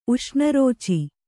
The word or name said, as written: ಉಷ್ಣರೋಚಿ